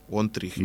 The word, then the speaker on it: One Tree Hill: Y en este caso, pues vamos a ir rapidito y, y comentamos de que On Three Hill, Regresa también a la 2. One Tree Hill.